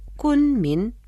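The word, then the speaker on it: Кунмин (昆明): Кун произносится в первом тоне, а Мин читается во втором тоне. Повторяйте за мной. Кунмин.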